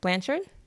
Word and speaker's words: Blanchard: Blanchard? – Oh, okay. – How do you pronounce that? – Blanchard? – Blanchard? Mm-hmm. Yeah, okay.